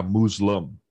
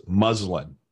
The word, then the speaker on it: muslin: You don't have to say it like it's a Muslim. It's Muslin.